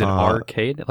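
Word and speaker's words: Aurcade: Is it Arcade? Like A-U-R-cade? – Yes. – It's spelled weird.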